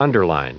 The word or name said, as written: underline